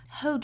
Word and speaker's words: whole: Whole 这个词，我们注意英音的话，元音是一个 o 的发音，所以 whole 最后呢，舌侧音要求我们舌尖去顶到上齿龈的位置哈。